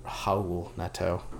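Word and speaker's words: Raul Neto: In order to get out there, I had to check the pronunciation one last time so I don't disrespect Cavaliers' soon point guard, Howl Neto.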